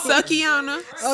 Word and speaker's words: Sukihana: That's she is a she is a Is it Sukihana? Oh, we said her name right. – Sukihana.